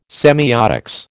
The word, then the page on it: semiotics: American English